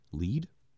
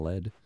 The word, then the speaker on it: Lead: Lead, I think is how they say it, not Lead.